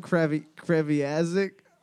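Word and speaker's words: Kreviazuk: How do you say it? – Chantal Kreviazik.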